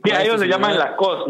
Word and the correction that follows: Lacoste: ¿Cómo se dice? la La Se dice Lacoste, obvio.